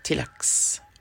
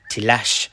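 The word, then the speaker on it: Tlax: The slan hailed from the temple city of Tilax, or Tilash, as Marco would constantly mispronounce it as.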